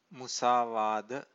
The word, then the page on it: musavada: Pali